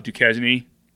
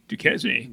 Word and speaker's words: Duquesne: It's spelled the French way, which would be Duquesne. – Yeah. But they pronounce it Duquesne.